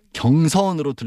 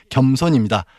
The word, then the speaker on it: 겸손: 근데 이게 겸손이 아니라 경선으로 들린다는 민원이 많은데 경선이 아니라 겸손입니다. 발음하기도 힘든 겸손.